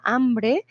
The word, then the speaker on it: hambre: Mili, por ejemplo, dice no pudieron dormir porque tenían mucha hambre. Hambre es de esas palabras como el águila, porque empieza con H, pero la pronunciación es una A.